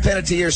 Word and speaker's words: Panettiere: So, Hayden Panettiere, someone says he had a daughter, I didn't realize this, with her former boyfriend.